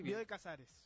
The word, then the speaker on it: Bioy Casares: creo que fue Bioy Casares. ¿Se dice así? – Bioy Casares.